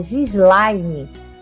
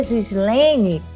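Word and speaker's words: Ghislaine: Meu nome se escreve com GH, Gislaine. Pronuncia Gislaine, mas é Gislaine que escreve com H, hein?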